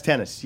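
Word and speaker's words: Tennys: Is it pronounced tennis? – It's pronounced tennis, yes.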